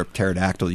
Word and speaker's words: pterodactyl: You know, one of the jokes that I love, why can't you hear a pterodactyl use the bathroom? Because the P is silent.